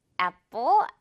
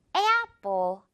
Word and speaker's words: apple: 그래서 apple 아니고 apple. 이렇게 발음이 됩니다. 그냥 단순한 에보다 약간 에야 같은 느낌이라서 이렇게 한번 표기해봤는데 도움이 될 거예요, 여러분.